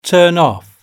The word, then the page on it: turn off: British English